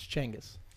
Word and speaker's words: Genghis: Everybody says Genghis. It's Genghis.